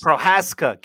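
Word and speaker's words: Procházka: But Yuri Prochaska over Dominic Reyes. – Prohaska. Get the name right if he's going to be the champ.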